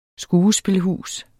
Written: skuespilhus

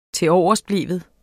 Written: tiloversbleven

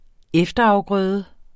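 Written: efterafgrøde

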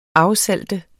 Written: afsalte

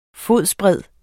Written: fodsbred

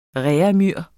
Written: reaumur

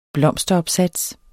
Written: blomsteropsats

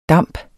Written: DAMPENE

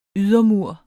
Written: ydermur

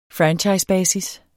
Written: franchisebasis

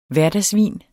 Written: hverdagsvin

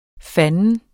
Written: fandens

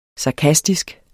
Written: sarkastisk

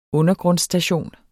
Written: undergrundsstation

